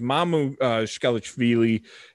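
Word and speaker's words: Mamukelashvili: They needed something from their guard play because Mamu Shkelechvili, and I hope I said that right. – Sounds good to me. He's Georgian. Yeah, you nailed it.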